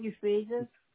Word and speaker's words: Euphrasia: St. Euphrasia.